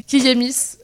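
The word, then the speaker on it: Kiyémis: C'est Kiemis. Du coup, ça s'écrit K-I-Y. E accent aigu, M-I-S, et du coup c'est une blogueuse et podcasteuse super intéressante que je vous conseille d'écouter et de lire.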